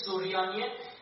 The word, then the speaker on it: سریانی: یونانی و سریانی تلفظ درستش سوریانیه